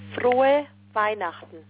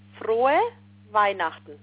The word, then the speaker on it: Frohe Weihnachten: Frohe Weihnachten. – Oh, say that one more time. – Frohe Weihnachten.